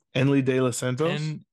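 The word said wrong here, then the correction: Enyel De Los Santos: Eniel De Los Santos. – Eniel. – Yes. – De Los Santos. – You got the pronunciation.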